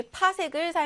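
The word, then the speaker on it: 파섹: 별까지의 거리는 우리 천문 단위 파색을 사용을 하고 있습니다. PC로 쓰고요. 우리가 읽을 때는 파색이다라고 읽어주시면 돼요.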